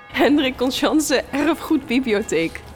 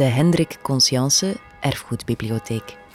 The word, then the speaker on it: Hendrik Conscience Erfgoedbibliotheek: Vlak bij de grote kathedraal in Antwerpen zit de Hendrik Conscience Erfgoedbibliotheek. – De Hendrik Conscience... Ik kan het niet uitspreken. – Ik ga er met Mirke naartoe. – Hendrik Conscience Erfgoedbibliotheek. Bibliotheek.